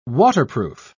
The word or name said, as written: waterproof